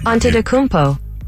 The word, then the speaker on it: Antetokounmpo: Antetokounmpo. – Ante Tecumpo.